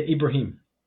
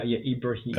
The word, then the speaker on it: Ibrahim: Yeah, Ibrahim. – Yeah, so it's got that E, that Ibrahim. Do you pronounce the H? – Yeah, Ibrahim. Oh, yeah.